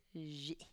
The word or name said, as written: zhi